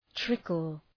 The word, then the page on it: trickle: ‘trıkəl